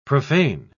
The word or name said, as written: profane